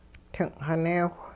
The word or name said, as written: ting'xineewh